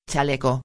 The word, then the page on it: chaleco: espanhol